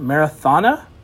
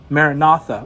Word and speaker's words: Maranatha: It's M-A-R-A-N-A-T-H-A. Is it Marathana? – Maranatha.